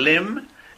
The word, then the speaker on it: limb: Ojo que en limb la B al final no se pronuncia.